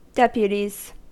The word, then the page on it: deputies: American English